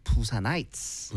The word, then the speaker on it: Busanites: Now, people from Busan, they are called Busanites. – Busanites. – 네, 스펠링도 B-U-S-A-N-I-T-E-S. 네, 부산의 밤들이 아니고요. Busanites입니다. 사람들.